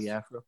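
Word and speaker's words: Biafra: Biafro.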